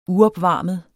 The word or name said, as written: UOPVARMET